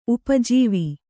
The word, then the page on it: ಉಪಜೀವಿ: upa jīvi